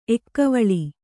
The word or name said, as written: ಎಕ್ಕವಳಿ